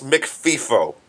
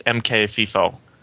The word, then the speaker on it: mkfifo: Fix an open baster bypass in POSIX McFIFO. There's a function in PHP called POSIX McFIFO. POSIX MKFIFO. – I think it's pronounced POSIX MKFIFO.